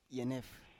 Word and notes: yenef: Biak

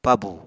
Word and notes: pabù: Apatani

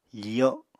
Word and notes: fiyo: apatani

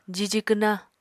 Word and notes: jiji kwna: apatani

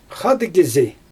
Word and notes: xaadɯggizi: Xyzyl

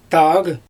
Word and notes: taaɣ: Xyzyl

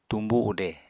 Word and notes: tumbu ude: Apatani

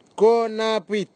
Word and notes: konapwit: Nafe; Tanna

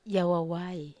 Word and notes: awawai: Wamesa

